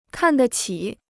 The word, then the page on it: 看得起: kàn de qǐ